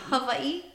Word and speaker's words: Hawaii: Hawaii? – Hawaii. – She says it, She says it correctly. Yes. Right? – Yeah. Very correct. The W is pronounced like a V.